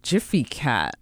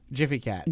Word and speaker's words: Gfycat: It's Jiffy Cat. – Jiffy Cat.